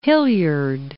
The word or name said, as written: Hilliard